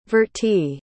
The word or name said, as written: vertie